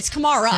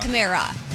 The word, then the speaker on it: Kamara: It's Kamara. – Yeah, Alvin Kamara. – Not Kamara.